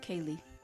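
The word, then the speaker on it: ceilidh: This St. Patty's Day, she planned a traditional Kaylee, do you call it? – Kaylee. – Okay. I put too much emphasis on the D, it seems. Kaylee. – Mm-hmm. Okay. Ignore the D.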